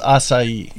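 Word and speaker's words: açaí: So that's how you pronounce it, is acai. – Yeah, the C with the little ding dong is like an S sound.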